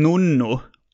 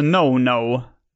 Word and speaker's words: No No: Jag gissar på att jag ville att det skulle uttalas en nonno. Men det är bara ett n, nonno, alltså no no.